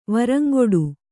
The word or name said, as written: ವರಂಗೊಡು